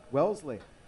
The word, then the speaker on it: Wellesley: I want to make sure I was pronouncing that hometown right. – Yeah. You got it. Wellesley.